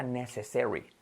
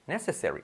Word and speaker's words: necessary: Entonces, fíjate, se pronuncia necessary, necessary, con acentuación la primera.